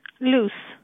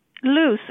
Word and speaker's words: Luz: Hi, did you say your name was Lusa? – Luce. – Lufa? – Luce, L-U-Z.